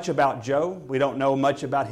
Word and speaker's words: Joel: And so the way you would pronounce his name in Hebrew, Joel, would be Yol.